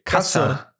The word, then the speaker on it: Kasse: In German, you say Kasse. – Kasse. – With an E at the end. So it's Kasse.